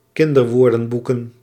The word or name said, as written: kinderwoordenboeken